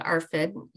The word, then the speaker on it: ARFID: And talking about Ben's eating disorder called ARFID, you were right, the way you pronounced it,